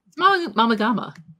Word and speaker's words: Mama Gama: Mama Gama. – Mama Gama? – Yeah. – Yeah, it's a hard G. It's not like a GIF.